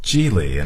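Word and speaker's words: Gigli: It's called Geely, and it's spelled G-I-G-E-L-I. – G-I-G-E-L-I.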